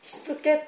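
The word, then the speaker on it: 躾: だからそれを私たちは強制的にまっすぐに戻す、しつけとも言うんですけども、しつけという漢字を皆さん書きますか。しつけという漢字はどう書くかというと、身、体ですね、身の横に美しいと書いて、これでしつけと読むんですね。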